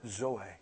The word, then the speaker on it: zoe: Z-O-E. We pronounce it Zoe.